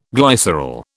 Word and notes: glycerol: British English

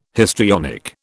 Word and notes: histrionic: British English

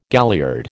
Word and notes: galliard: American English

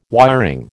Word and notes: wiring: American English

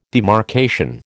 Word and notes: demarcation: American English

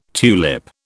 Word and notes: tulip: British English

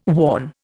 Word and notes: worn: Indian

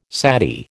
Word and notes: sati: American English

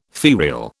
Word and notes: ferial: British English

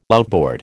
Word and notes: outboard: American English

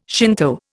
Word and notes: Shinto: Indian

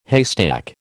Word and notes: haystack: American English

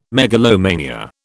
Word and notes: megalomania: British English

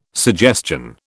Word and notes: suggestion: British English

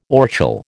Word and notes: orchil: American English

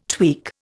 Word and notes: tweak: Indian

